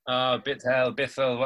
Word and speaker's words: Bithell: Bithell, whatever.